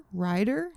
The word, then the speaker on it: Ryder: It's spelled R-Y-D-E-R, but writer? Ryder? – No, I think it's supposed to be Ryder. – Yeah. Oh, like ride her? – Yeah.